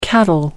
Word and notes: cattle: American English

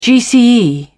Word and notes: gce: British English

